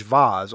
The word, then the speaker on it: Vase: The Challenge Vaz, or Vase, depending how you like to pronounce that word, is an annual football competition for teams playing below Step 4 of the English National League system.